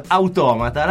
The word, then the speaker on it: Automata: Dopodiché volevo dirvi, il film Automata, l'accento è sulla O e non è spagnolo ma è greco.